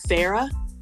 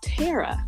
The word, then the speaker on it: Thera: People think her name, what they call you, do they really call you Thera? – Thera, Thera, yeah. – Because her name is spelled T-H-E-R-A. You know, but it's pronounced Tara.